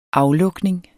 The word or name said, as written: aflukning